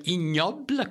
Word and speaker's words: Ig Nobel: Hein, euh, ensuite, il y, il y, a la stupidité en sciences aussi avec euh, les, prix euh, IG Nobel qu'on appelle ignoble, qu'on prononce comme ça, puis le prix Darwin.